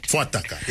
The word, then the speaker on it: Fuataka: Uh, the first one is Fuataka. Is, did I say it right? Fuataka, yes. Fuataka.